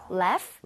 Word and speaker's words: laugh: Laugh. – Laugh? Okay. 자, 그러면 여기 써주세요. – Laugh at... – 스펠링을 제대로 만들어주세요. – Laugh... 끝 같은데요? – GH는 F와 비슷하게 발음이 됩니다. 네.